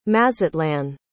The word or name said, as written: mazatlán